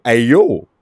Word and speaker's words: Ayo: And if you were to pronounce them all together, it basically sounds like Ayo, which is a very common word in most Dravidian languages, including Telugu.